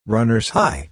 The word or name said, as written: runners high